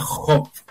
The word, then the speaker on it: Hob: que es un juego indie que se llama Hop, que se escribe con H-O-B, que es un juego súper desconocido pero es muy tipo Zelda y está entretenido.